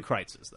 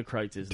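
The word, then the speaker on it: Kreuz: Go to Kreitz's, though.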